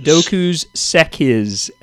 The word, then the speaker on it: Dokuz Sekiz: Dokuz, Dokuz, Dokuz, Dokuz, Sekiz, S E K I Z, um, yeah.